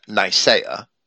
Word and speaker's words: Nicaea: of the 318 bishops held at Nicaea, some people might pronounce that Nicaea,